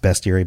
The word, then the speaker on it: Bestiary: It's pronounced Bestiary, but it's about beasts.